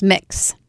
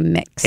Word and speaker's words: Mx: Pronounced mix. – Mix. – Mm-hmm. – That was going to be my question. How do you pronounce it? – You say mix.